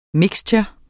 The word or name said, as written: MIXTURENE